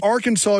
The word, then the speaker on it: Arkansas: Arkansas is the way you have to pronounce it.